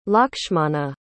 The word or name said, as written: Lakshmana